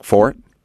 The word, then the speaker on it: forte: Forte, and he's like Fort. And I said no, I think it's Forte. – Yeah, it's pronounced Fort.